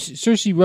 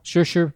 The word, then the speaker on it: Saoirse: Saoirse Ronan, is that her name? Am I saying it right? – No. – Saoirse. – Right.